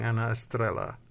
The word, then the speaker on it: Anna Estrella: Yeah, in the Spanish, the trilling of the tongue, Anna Estrella.